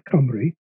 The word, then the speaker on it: Cymru: I won't bother about how it's spelled, but it's pronounced Cymru.